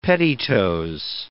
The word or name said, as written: pettitoes